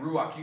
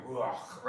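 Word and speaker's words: ruach (רוח): Ruach. You gotta almost say the second part like you're clearing your throat. Like you gotta look at ruach, right? Ruach is how you say it.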